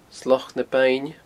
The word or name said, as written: Sloc na Beinne